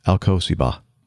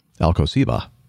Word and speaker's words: Alcosiba: And again, that's Albert, and he gave me a phonetic pronunciation guide. Alcosiba. Alcosiba. I don't know where the accent goes there.